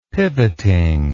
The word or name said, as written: pivoting